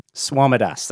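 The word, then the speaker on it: Swamidass: Swamidas, that's how you pronounce his name.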